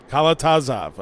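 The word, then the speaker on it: Kalatozov: Mikhail Kalatazov? How do you say it? – Kalatazov, okay.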